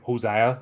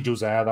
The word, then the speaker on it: Josiah: What Josiah saw, now I keep thinking Hosea, you know, like just been around too many Spanish people. But it's actually Josiah, that's the way they pronounce it.